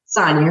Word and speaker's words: Sonya: Sonya is fine.